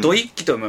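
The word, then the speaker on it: 土一揆: これ土一揮と読む,、うん、読むらしいんですけど、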